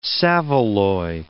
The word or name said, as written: saveloy